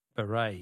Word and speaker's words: beret: Words that end with a silent T. Beret is B-E-R-E-T.